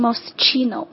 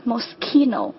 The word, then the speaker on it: Moschino: Moschino， OK， Moschino，不要把它发成 Moschino，正确的发音应该是 Moschino，就是像钥匙 key 的这个音一样哈， Moschino。